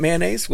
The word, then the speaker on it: mayonnaise: Isn't it mayonnaise? What's it supposed to be? – Mayonnaise. Oh. It's three syllables.